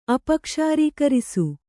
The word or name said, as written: ಅಪಕ್ಷಾರೀಕರಿಸು